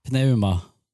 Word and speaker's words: Pneuma: Pneuma. – Jag heter den bara så? Ah, okay. – Alltså pneumatik utan pneuma. P, P är ju tyst.